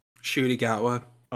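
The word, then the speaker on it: Ncuti Gatwa: Shooty Gatwa.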